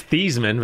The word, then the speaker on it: Theismann: And his name is Theismann, but they change it. – Yeah, this is spelled to rhyme with the Heisman.